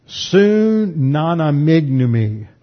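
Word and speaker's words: sunanamignomi (συναναμίγνυμι): This is the Greek word sunanamignomi. Sunanamignomi. That is spelled S-U-N, for those of you on tape, S-U-N-A-N-A-M-I-G-N-U-M-I.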